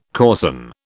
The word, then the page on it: coarsen: British English